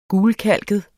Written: gulkalket

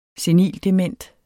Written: senildement

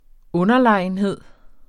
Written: UNDERLEGENHEDEN

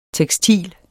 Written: tekstil

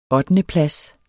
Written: ottendeplads

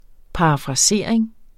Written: parafrasering

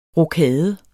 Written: rokade

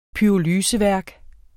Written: pyrolyse

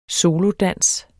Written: solodans

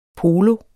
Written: polo

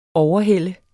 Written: overhælde

